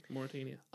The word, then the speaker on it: Mauritania: Mauritania. – Yeah, there you go. Mauritania.